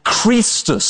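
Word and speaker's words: Chrestus: Well, Suetonius tells us because they were squabbling over one called Christus, spelt with an E in Suetonius' work, and it was pronounced with a long E, so very like how it would have been pronounced if it was an I, Christus as well.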